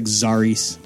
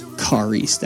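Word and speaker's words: Xaris: I know it looks like Zaris, but because it's an X, but it's Karis. That's how you pronounce it. It's a Greek word.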